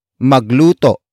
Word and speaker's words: magluto: The first word is magluto. Magluto. Magluto. Magluto is spelled as M-A-G-L-U-T-O. Note the glottal stop at the end.